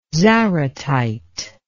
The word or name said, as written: zaratite